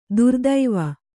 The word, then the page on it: ಧೀರತ್ವ: dhīratva